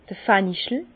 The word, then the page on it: d'r Fenchel: Bas Rhin; Herrlisheim